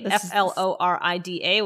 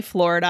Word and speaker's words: Florida: I say Florida. I pronounce O, but a lot of people who I think it's a Jersey thing too would say what? – Florida. – Yeah. Yeah. That sounds very New Jersey to me.